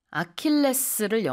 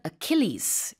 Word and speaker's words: Achilles: 아킬레스를 영어로는 Achilles 이렇게 발음을 합니다.